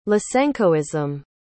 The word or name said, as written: lysenkoism